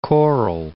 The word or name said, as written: Cape Coral